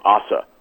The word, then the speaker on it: Ose: This guy, I think you pronounce his name Osse, O-S-E.